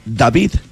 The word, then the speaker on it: David: Y deberíamos decir, deberíamos decir David Guetta, no David. – Ah, bueno. – Si él es francés, Bueno, en francés es David. – David. – Así se pronuncia.